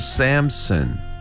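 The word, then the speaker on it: Samson: 마노아를 마노아 이렇게 발음하시면 되고 그 다음에 삼손은 샘슨. 샘슨. 한 번 더요.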